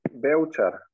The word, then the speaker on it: Bevcar: Oh, Bevcar. – Belcher. So like it sounded like an L in the middle.